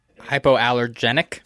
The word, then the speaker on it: hypoallergenic: T-shirts, hyperallergenic. Allergenic. How do you say it? – Hypoallergenic. – There you go.